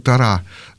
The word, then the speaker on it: Тора: А вот в Торе, или в Торе, если на еврейский манер, ударение на последний слог, Тора, так там вообще об этом ни слова, очень-очень скупо.